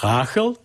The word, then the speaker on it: Rachel: En Rachel, je moet het eigenlijk uitspreken als Rachel, want dat vond zij heel vervelend.